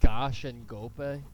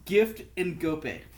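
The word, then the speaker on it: Ngoepe: Isn't it like Gosh and Gope? – It's Gift and Gope. – Gift and Gope. – Gift, like present.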